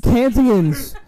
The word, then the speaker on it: Kansans: Wait, how do you say it? Kansas people? – Kansans. – Kansians, my guy.